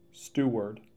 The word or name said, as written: Steward